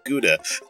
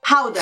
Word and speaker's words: Gouda: It's not Gouda. – Gouda? Howda.